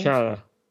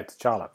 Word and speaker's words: T'Challa: T'Challa, is that right? – T'Challa. – Yeah, T'Challa. That's it. T'Challa.